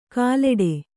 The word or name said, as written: ಕಲ್ಲೆಡೆ